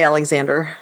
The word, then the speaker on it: Alexandre: Alexandre. Let's just say Alexander. – Alexander Dumas. – Just English it.